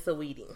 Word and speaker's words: Saweetie: So it is Saweetie. – Saweetie? – Saweetie. – Saweetie. You gotta pronounce the S-A.